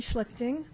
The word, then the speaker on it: Schlichting: My name is Sally Schlichting. That's spelled S-C-H-L-I-C-H-T-I-N-G.